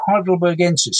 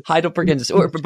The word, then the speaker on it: heidelbergensis: Named, yeah, Heidelbergensis. So Heidelbergensis. But before going deep into it. Oh, got it. – So Heidelbergensis.